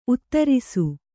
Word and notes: ಉತ್ತರಸ: kannada

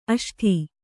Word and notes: ಅಷ್ಟು: aṣṭu

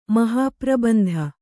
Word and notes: ಮಹಾಪ್ರಬಂಧ: mahāprabandha

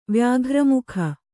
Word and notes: ವ್ಯಾಘ್ರಮುಖ: vyāghra mukha

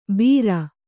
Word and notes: ಬೀರ: Kannada

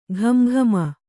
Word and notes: ಘಮಘಮ: Kannada

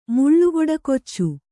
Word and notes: ಮುಳ್ಳುಗೊಡಕೊಚ್ಚು: Kannada

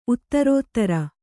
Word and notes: ಉತ್ತರೋತ್ತರ: uttarōttara

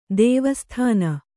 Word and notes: ಧೀವಸತನ: Kannada

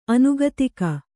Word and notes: ಆನೆಗದುಗ: ānegaduga

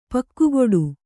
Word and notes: ಪಕ್ಕುಗುಡು: kannada

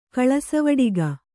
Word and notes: ಕಳಸವಡಿಗ: Kannada

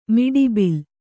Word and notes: ಮಿಡಿಬಿಲ್: miḍi bil

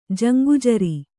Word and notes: ಜಂಗುಜರಿ: kannada

